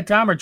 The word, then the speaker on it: Dahmer: So moving on, we have another streaming review to Netflix, and it's Dahmer. Am I pronouncing that right? – Yeah, Dahmer.